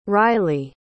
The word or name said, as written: ryley